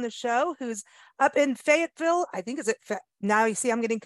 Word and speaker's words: Fayetteville: It's definitely Fayetteville.